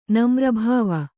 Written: ನಮ್ರಭಾವ

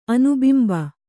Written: ಅನುಬಿಂಬ

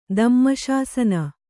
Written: ದಮ್ಮಶಾಸನ

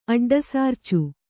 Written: ಅಡ್ಡಸಾರ್ಚು

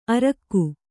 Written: ಅರೋಗ

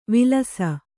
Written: ವಿಲಾಸಿ

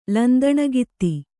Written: ಲಂದಣಗಿತ್ತಿ

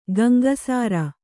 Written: ಗಂಗಸಾರ

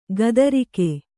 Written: ಕಾತರಿಕೆ

